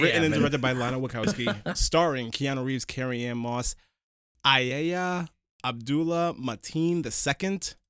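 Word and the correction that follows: Yahya: Yaya. His name is Yaya.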